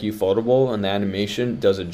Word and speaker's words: Ufotable: My number two is, I don't know if I'm going to pronounce it correctly, but Ufotable. – Ufotable is correct.